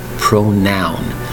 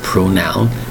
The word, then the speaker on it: pronoun: That is a pronoun, spelled P-R-O-N-O-U-N, pronoun. You'll notice there is no W in that word. It's pronounced pronoun, P-R-O-N-O-U-N. It's pronoun.